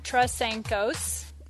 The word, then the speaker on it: Trasenkos: My name is Stacy Trasenkos, and that is how you pronounce my last name. It gives just about everybody, including my own parents and children, trouble saying it and spelling it.